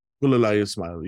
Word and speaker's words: Gulalai Ismail: Speaking of which, let's focus a little bit on, I'm going to say her name wrong, but Gulalai Ismail. – Gulalai Ismail, yeah.